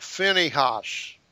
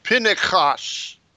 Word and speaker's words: Pinchas: Now they have Phinehas. Mm-hmm. Well, it's pronounced Pinchas. That's a C-H, not an H.